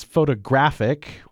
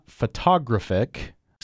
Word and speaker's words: photographic: Yeah, the last one here in our series is photographic, which is not photographic. So we have another four-syllable word, but the stress is in a different place this time. – Words that end in ick. With the suffix IC, like ecstatic, geographic, you usually stress the antepenultimate syllable. – No, the penultimate syllable.